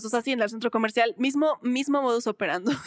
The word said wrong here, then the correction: operandi: operandi, ¿cómo se dice? Operandi, ajá.